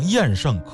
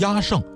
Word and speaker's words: 厌胜: 您比方说有一种巫术叫厌胜，厌就是讨厌的厌，这个呀念成厌胜可以，念成压胜也可以，有听众提出来呢就可以交流了。